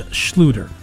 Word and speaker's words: Schluter: Now, a lot of people say his name a lot of different ways. Schluter, Schlater, Schleider. But I think it's Schluter. I think that's how he pronounces it.